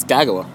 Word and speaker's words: Kagawa: I've always been saying Kagawa, but I looked at the pronunciation. It's Kagawa.